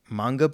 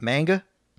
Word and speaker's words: manga: Like manga books, I guess is how you say it. It's not manga.